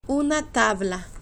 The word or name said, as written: una tabla